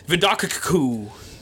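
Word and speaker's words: Vidocq: It's V-I-D-O-C-Q... So I assume that's pronounced Vidocku. – Close enough.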